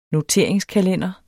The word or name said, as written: NOTABELT